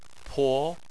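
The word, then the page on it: po: tone 2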